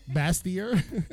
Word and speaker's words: Bastyr: Studied interior design at the University of Georgia and has a doctorate from the prestigious, how do I say that university? Bastyr University? – Bastyr.